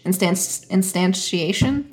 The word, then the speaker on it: instantiation: Just, uh, you're, you're pronouncing it with like a like an S. It's like with this sh. Instant instantiation.